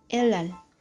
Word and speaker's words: Helal: Helal.